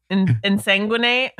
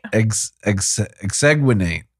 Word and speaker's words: exsanguinate: I know. Insanguinate? – Exsanguinate.